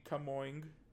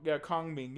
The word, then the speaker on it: Kongming: Kongming. Your Boy Kongming. – Okay. – So, it's about if you don't know, well, you apparently you don't know, Jay. Kongming is a historical Chinese general, and Okay.